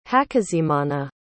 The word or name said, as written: hakizimana